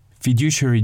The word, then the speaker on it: fiduciary: Let's learn a new word, fiduciary duty.